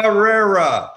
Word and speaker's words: Pereira: Pereira. – Pereira. Pereira. Pereira. Uh. Pereira. Think about Pereira. Th- think about pa, and then the way we like steak, rare. – Pereira.